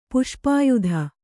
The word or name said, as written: ಪುಷ್ಪಾಯುಧ